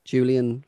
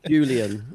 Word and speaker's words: Julian: pronouncing it right? Julian. It's a mouthful, isn't it? – Yeah, it's like, especially when somebody makes you when I sort of somebody makes you suddenly question it. Do you know what I mean? So now you're like, is it? Am I saying it right?